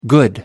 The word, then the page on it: good: American English